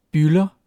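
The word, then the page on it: bylder: Danish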